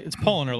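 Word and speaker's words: Paulaner: And then Adam Gold over in the Facebook chat says, I'm enjoying I can never say this right. I think it's Polliner Hefeweizen.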